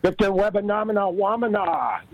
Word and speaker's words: Victor Wembanyama: Victor Wembanyama on the headline. Get in here and talk to us. It's Donnie Kevin right here on Moneyline. – Victor Wembanyama.